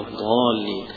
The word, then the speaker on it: الضالين: لكن لا تنطق الضاد ضاء زي ما البعض يقول لك هي هذا مخرجها هذا وتقرأ، ولا الضالين يقرأها قريبا من الضاد أو يقرأها كالضاد، هذا ليس بصواب.